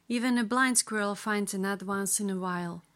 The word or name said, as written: blind squirrel finds a nut once in a while